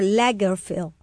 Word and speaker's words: Lagerfeld: Sí, y por otra parte, no sé si has visto visualmente algunos reportajes de el famoso diseñador Lagerfeld. ¿Se dice así, amigo mío? – Uh-huh.